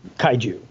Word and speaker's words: Kaiju: Kaiju.